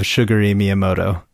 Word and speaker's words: Shigeru Miyamoto: Okay, now what is the proper pronunciation of Shigeru Miyamoto? – I think you just nailed it.